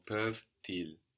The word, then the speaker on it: peuvent-ils: Ah, you only hear that last consonant. And we hear it because it's sticking on to the next word. It's not on the end of the syllable anymore. No, it's the beginning of a new syllable. So, peuvent-ils.